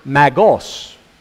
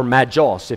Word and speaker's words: Magos: And the reason they're called that, the word is spelled M-A-G-I, Magi. And it's the plural of the word Magos, M-A-G-O-S, or Magos, if you want to say it with a J there. Now, that Magi, you might, just from maybe spelling it out in your mind, M-A-G-I, finish it like this, C-I-A-N-S, magician.